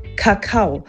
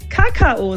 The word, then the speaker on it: Kakao: Also, ich würde ja sagen, die vorherrschende Aussprache im deutschsprachigen Raum ist Kakao. Man kann es sich vielleicht so merken, dass ich ans Kauen denke, ja, Kakao, und dann eben auf die zweite Silbe betone. Aber, und jetzt kommt eben das Schöne, es darf anscheinend auch jeder Kakao sagen. Und hier ist jetzt die Betonung auf der ersten Silbe.